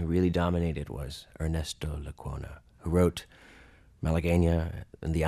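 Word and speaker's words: Lecuona: And how do you say, is it Lacona? – Ernesto Lacona.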